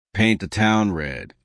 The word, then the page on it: paint the town red: American English